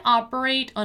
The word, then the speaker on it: operate: We have the word operate, which has this ah sound, right?